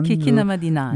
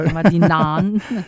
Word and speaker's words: Kikinamadinan: Ben oui, j'ai demandé à des gens, puis j'ai pas la prononciation parfaite, parce qu'il y a une espèce de Kikinamadinan. – Kikinamadinan. – Il faut comme ouvrir le « an » à faire. – Kikinamadinan.